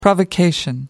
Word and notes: provocation: American English

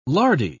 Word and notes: lardy: British English

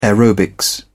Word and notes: aerobics: British English

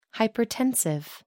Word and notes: hypertensive: American English

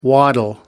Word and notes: waddle: American English

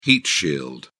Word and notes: heat shield: British English

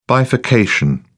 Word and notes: bifurcation: British English

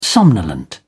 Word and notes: somnolent: British English

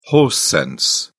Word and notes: horse sense: British English

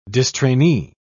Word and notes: distrainee: British English

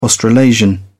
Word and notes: australasian: British English

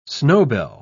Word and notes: snowbell: British English

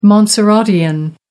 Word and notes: montserratian: American English

